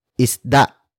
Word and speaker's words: isda: It is an object marker that introduces what was caught, and it was isda. Isda. Isda. Isda is spelled as I-S-D-A. Note the glottal stop at the end.